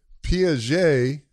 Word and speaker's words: Piaget: So Piaget, I think I'm saying his name right.